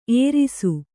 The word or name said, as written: ಎರಸು